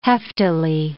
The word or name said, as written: heftily